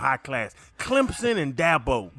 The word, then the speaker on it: Dabo: Dabo.